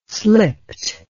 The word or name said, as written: slipt